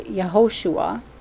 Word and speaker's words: Yehoshua: If you want to look at, at Joshua, it's actually Yehoshua is how you pronounce his name.